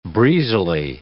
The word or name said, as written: breezily